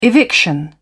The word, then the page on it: eviction: British English